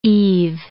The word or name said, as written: eve